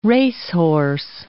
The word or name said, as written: racehorse